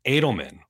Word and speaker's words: Edelman: So was that David Alderman or was that Josh Harris? – It's Edelman. Why can't you say his name? – Listen, I grew up with an Ottoman.